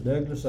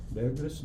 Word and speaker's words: Douglas: My name is Douglas A. Nicholson. – And if you'll spell it? – Douglas, D-O-U-G-L-A-S, initial A, Nicholson, N-I-C-H-O-L-S-O-N.